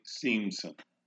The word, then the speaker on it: Siemsen: Seamson. That is spelled S-I-E-M-S-E-N.